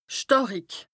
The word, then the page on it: stàrik: Bas-Rhin; Strasbourg